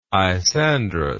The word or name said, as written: isandrous